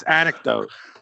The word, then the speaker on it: anecdote: I would have tried to say antidote. – And you're still going with that? It's anecdote.